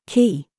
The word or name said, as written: COOK